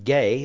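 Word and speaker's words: Gay (γῆ): Gay is the way this word is pronounced, and it means ground or dirt.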